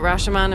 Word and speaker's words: Rashomon: I loved the, I guess you could say the, is it Rashomon? Am I saying that right? – Yeah.